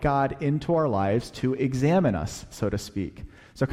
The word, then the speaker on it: examen: It's been new to me over the past 6 months or so, called examine. It's spelled E-X-A-M-E-N, although it sounds like examine, like to examine, like your doctor examines you when you go in for your yearly checkup.